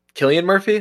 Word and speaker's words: Cillian Murphy: But Well, even like just recently, like, is it Cillian Murphy?